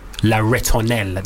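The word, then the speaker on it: La Ritournelle: and I chose Sébastien Tellier's La Ritonelle.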